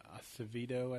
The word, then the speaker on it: Acevedo: And you got Kirk and Acevedo, I think is how you say his name. I may be mispronouncing that. – No, I think that's right.